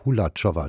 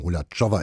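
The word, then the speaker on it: Hulačová: Abundantia, Skulpturen der tschechischen Künstlerin Anna Holatschowa. Entschuldigung, Holatschowa ist es richtig ausgesprochen. Ich habe das vorhin etwas hart phonetisch hier Ihnen vermittelt.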